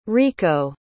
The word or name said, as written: riko